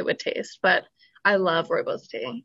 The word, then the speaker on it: Rooibos: I think I'm saying this right, Robos. – Rooibos, yeah.